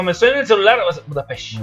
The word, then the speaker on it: Budapest: No, es que se pronuncia Budapest.